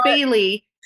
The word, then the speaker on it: Baylee: But my name is Bailey, and it's B-A-Y-L-E-E. So it's phonetically correct. So like Bay and then Lee, and then there's a period, and then and C-O.